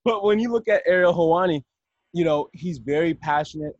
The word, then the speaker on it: Helwani: How do you pronounce his name? – I think it's Ariel Helwani. – Ariel Helwani, that's how it is.